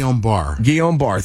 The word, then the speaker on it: Guillain-Barre: That AstraZeneca vaccine has also been linked to a higher risk of, is it Guillain-Barre? I can't pronounce half this stuff. – Guillain-Barre. – Thank you, syndrome. That's a neurological disorder.